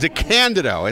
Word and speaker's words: DeCandido: I pronounced it all DeCandido. – Correct? DeCandido. It's on this, the accent. – Everybody gets it wrong. It's fine.